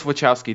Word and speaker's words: Wachowski: I know how to pronounce it in Polish. I don't know how they pronounce it. – No, it's Wachowski.